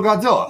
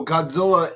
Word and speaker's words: Godzilla: Well, no, Godzilla is like the Americanized pronunciation.